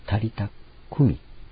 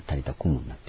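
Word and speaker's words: タリタ・クミ: タリタクミという言葉ですね。本来アラム語、ヘブライ語ではクミという発音なのですけれども、それをギリシャ語に訳したときクムという発音で音を移したために、新教訳だけがタリタクムになっていますが、本来タリタクミなんですね。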